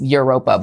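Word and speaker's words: Evropa: It's Europa, but they spell it with a V because they're pretentious.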